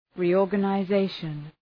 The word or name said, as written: reorganization